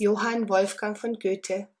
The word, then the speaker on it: Goethe: G-O-E-T-H-E. Goethe.